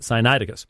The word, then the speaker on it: Sinaiticus: I'll say it right someday. It's just so natural. It's not natural to say Sinaiticus. It's just natural to throw almost a leveling vowel in there. It's really interesting, but Sinaiticus.